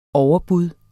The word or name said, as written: overbud